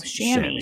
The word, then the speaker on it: chamois: C-H-A-M-O-I-S? – Oh, Chamois. – Chamois. So first off,